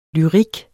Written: lyrik

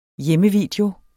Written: hjemmevideo

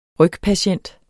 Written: rygpatient

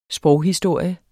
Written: sproghistorie

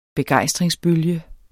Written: begejstring

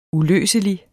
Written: uløselig

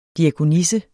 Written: diakonisse

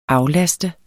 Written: aflaste

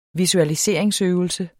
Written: visualisering

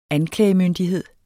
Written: anklagemyndighed

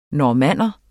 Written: normanner

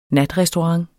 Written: natrestaurant

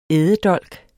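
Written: ÆDEDOLKENE

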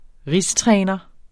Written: rigstræner

